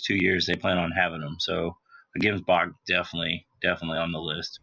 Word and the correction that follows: Gemsbok: All right, can I correct you for a minute? It's Gemsbok. Okay. You got to say it right if you're going to hunt it out of South Africa.